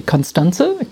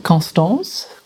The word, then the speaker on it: Constanze: So I don't mind whether people say Constanze, Constance, Constance.